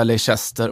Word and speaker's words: Leicester: Man ska säga Leicester,